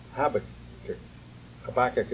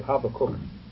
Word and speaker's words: Habakkuk: And the prophet Habakkuk, as we would say. In German, we say Habakkuk. So if I give you both translations, pronunciations.